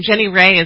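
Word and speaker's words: Jenny Rae: And we spell that J-E-N-N-Y, last name R-A-E. – That's actually my first name. Jenny Ray is my first name.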